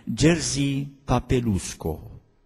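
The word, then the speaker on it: Jerzy Popiełuszko: un nume polonez, și numele poloneze sunt mai greu de pronunțat, dar pronunția corectă cred că ar fi Jerzy Papelusko.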